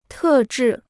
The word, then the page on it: 特制: tè zhì